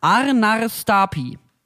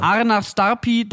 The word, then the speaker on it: Arnarstapi: Arnarstapi. a r n a r s t a Da sollen die Leute jetzt hinfahren. – Arnarstapi, da war ich.